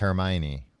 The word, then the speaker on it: Hermione: I think when you would buy Harry Potter, they would tell you it's pronounced Hermione.